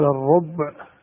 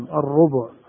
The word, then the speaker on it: الربع: يقال الربع أصلها بالضم الربع لكن تلفظ بالسكون تخفيفا, ولهن الربع, فالربع بالسكون والربع بالضم بمعنى واحد.